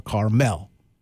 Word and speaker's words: Carmel: When I look at everything, I'm scrolling through the interwebs here, and everything's showing it as you put the highlight on the second syllable, Carmel. Carmel.